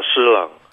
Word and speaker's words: Shi Lang: And the excerpt for this says, while Shi Lang, how do you say his name? – Uh, Shi Lang.